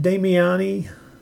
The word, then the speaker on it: Damiani: Damiani.